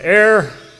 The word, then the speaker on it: heir: An heir, H-E-I-R, without the H in the pronunciation, an heir is a person who inherits.